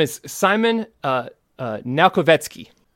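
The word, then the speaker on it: Naukowiecski: Now Kovetsky. – Yes, now Kovetsky.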